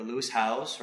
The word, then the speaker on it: Howes: I'm like somebody that I never potentially met, like a, you know, like a Lewis Howes, right? Said it right.